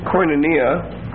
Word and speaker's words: koinonia: The word for fellowship is koinonia. Very familiar word. K-O-I-N-O-N-I-A. K-O-I-N-O-N-I-A. The second O is a long O.